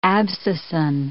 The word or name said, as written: abscisin